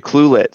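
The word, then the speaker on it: Ucluelet: You Yucluelet. So it's a U and then the word clue and then the word yet and the word let. You Yucluelet. – Yucluelet.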